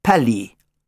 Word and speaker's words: Palis: P-A-L-I-S, pronounced Pally.